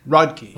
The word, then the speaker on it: Rodkey: Rodkey. Like it looks.